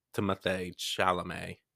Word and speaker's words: Timothée Chalamet: So recently I saw a preview for a new Willy Wonka of some sort with Timothée Chalamet, right? – Mm-hmm. – That's how you say his name, because he's French.